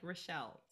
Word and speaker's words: Rachelle: And my name is spelled like Rachelle. So you got to put all the L's and all the E's in.